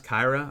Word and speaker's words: Kira: I love Kira Lewis. I've heard his name pronounced Kyra. I've been saying Kira for forever.